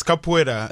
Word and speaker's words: capoeira: Tell me, I mean, like capoeira, is that reasonably close pronunciation? – Exactly. It's capoeira.